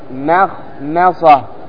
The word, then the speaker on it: مخمصة: لاحظ مخمصة، كيف تنطق الميم؟ تقول ماء ثم تقول خاء ثم ماء ثم صاء. إذن الميم وقعت بين حروف استعلاء فيجب العناية بها. أنطق معي مخمصة مخمصة مخمصة مخمصة. احذر أن تقول ماذا؟ ما مخمصة مخمصة خطأ، لأنك قلت ما لكن تقول ما مخمصة مخمصة مخمصة، وليس بينه وبين تركه إلا رياضة امرئ بفكه.